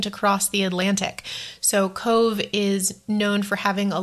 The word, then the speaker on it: Cobh: It's also very close to a a city, not not a city, maybe a town called Cobh, which is spelled C O B H because Irish is very different.